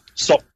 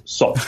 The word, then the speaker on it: Suk: But the way in which this would be pronounced in Khmer, now that I've done this boring thing, is Suk.